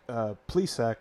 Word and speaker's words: Plesac: But so far, Zach Plesac, which I think I'm saying that right. Just like Dan Plesac, right? – Yep.